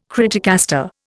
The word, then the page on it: criticaster: Indian